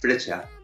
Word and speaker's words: Freccia: A mí me tocó, me ha tocado la cajiva frecha, o frecia, o cómo se pronuncia. Frecha. – Frecha.